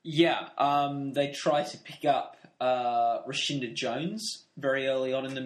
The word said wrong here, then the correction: Rashida: Don't think that's her name. – Yes, it is. Rashinda Jones. Am I pronouncing it wrong? – Thinking about it, an N. I think it's Rashida. – Oh, Rashida. Okay.